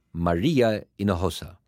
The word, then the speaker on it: Maria Hinojosa: Maria says her name in Spanish, Maria Hinojosa.